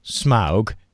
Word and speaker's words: Smaug: By the way, I looked up the pronunciation for Smaug, and actually, according to most of the sources that I was able to find, it's Smaug and not Smaug. I kind of keep in between the two, like smog, but you can just say Smaug, like wow, and that seems to be the official pronunciation.